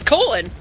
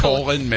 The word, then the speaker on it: Colon: How is the city pronounced? – It's Colon. – Are you serious? – Yeah, that's literally pronounced Colon, Michigan.